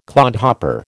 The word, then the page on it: clodhopper: American English